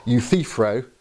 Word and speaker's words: Euthyphro: I always mispronounce this. I have a slight lisp, and so I find pronouncing Euthyphro, that was better, Euthyphro, quite hard.